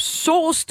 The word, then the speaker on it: Soos: Og så vil jeg gerne byde velkommen til den næste gæst, som er Philip Sos. Du må lige sige til, hvis jeg ikke udtaler dit efternavn rigtigt. – Jamen, det er sådan set øh, fint nok udtalt.